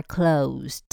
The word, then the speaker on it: close: 那如果你超过营业时间去看它，它的门口就会挂上 We are closed，尾巴是有滴的，这里一定要非常的小心。好，再来我们来看它形容词的发音， close 是 s， A is close to B， A 和 B 很接近。